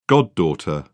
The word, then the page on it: goddaughter: British English